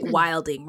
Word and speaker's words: wilding: It's like I don't know, like a term like wilding, right? It's spelled W-I-L-D-I-N-G, but Black people say wildin', right?